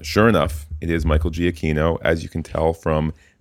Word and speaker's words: Giacchino: Soft G, hard K. Kino, not Chino. So, indeed, Michael Giacchino.